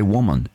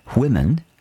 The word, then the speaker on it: women: Um, but they'll say woman. And uh, all Kiwis do this. They don't say women, they say woman and it's not. That's not how it's pronounced.